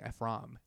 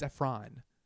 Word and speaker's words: Ephron: Nora Ephron, why am I saying Ephron? It's Ephron.